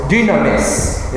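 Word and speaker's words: dynamis (δύναμις): The Greek word for power is dynamis, is how they pronounced it.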